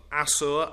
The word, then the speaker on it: Assur: Now, occasionally in scripture, they are referred to not only as the Assyrians, but also as the people of Assur, A-S-S-U-R, or Ashur. As you probably know, the S-H sound was more of a dialect or a local pronunciation of a letter in Semitic languages, so we find S and S-H used interchangeably.